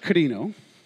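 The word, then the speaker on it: krino (κρίνω): Now, the word in Greek that's used for judge here is the word krino.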